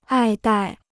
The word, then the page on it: 爱戴: ài dài